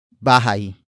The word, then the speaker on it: bahay: And that object is bahay. Bahay. Bahay. Bahay is spelled as B-A-H-A-Y.